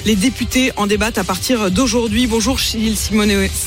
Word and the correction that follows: Simeoni: Gilles Simeoni, je ne sais jamais s'il faut que je le prononce en corse.